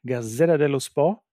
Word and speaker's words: Gazzetta dello Sport: Für die Nationalspieler Julian Draxler und Thilo Kehrer könnte sich eine neue Karriereperspektive ergeben, wie die Gazzetto dello Sport berichtet. Habe ich das gut ausgesprochen? Gazzetta dello Sport, so ein bisschen Jovial dahin.